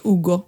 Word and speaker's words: Hugo: Men Hugo uttalas Ugo.